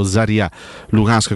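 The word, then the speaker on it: Zorya Luhansk: È una trasfertaccia quella in Ucraina, non perché lo, lo Zoria o lo Zaria Lukansk, come si dovrebbe pronunciare, sia una squadra fenomenale, ma proprio perché è una trasferta scomodissima.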